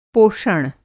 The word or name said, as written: पोषण